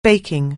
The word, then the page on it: baking: anglais